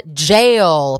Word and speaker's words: Jale: It's a band called Jail, but it's spelled J-A-L-E, not like J-A-I-L, as in you are going to jail. But it is jail as in like a gale, like a gust of wind, but with a J.